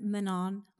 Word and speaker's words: Manon: So, Manon. Did I say that? Manon? – Manon? Yeah, like they don't pronounce the last N.